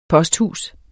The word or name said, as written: posthus